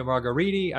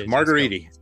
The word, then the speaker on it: Margheriti: So we're here to talk about Antonio Margheriti. I always say his name wrong. That's close enough for me. You guys, that is perfect. Margheriti.